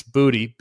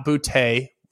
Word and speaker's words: Boutte: Meanwhile, when you look at what's going on at wide receiver, Kayshon Booty, for those that are saying that we're mispronouncing it, it's Booty. B-O-O-T-E-E is the pronunciation guide chart. So it is Kayshon Booty. All right. Not Boutte. It used to be Boutte. It changed this offseason.